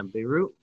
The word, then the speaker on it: Beirut: Prayers out to I think it's pronounced Beirut. And the Lebanese capital? – Yeah. – I don't know if you saw that huge explosion over there. Yeah, Beirut.